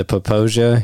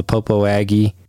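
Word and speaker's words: Popo Agie: The Poposia here in Fremont County, where I live, a lot of people call the Popo Aggie because that's how it's phonetically spelled.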